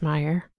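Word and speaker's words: Meijer: M-E-I-J-E-R. I assume it's pronounced Meyer. I don't know. – Yeah. Yes.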